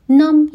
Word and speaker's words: numb: نام یعنی بی‌حس، بی آخرش تلفظ نمیشه.